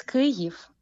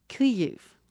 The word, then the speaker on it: Kyiv: What's the correct way to pronounce Ukraine's capital? – It's Kyiv. – Kyiv.